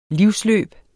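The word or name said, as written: livsløb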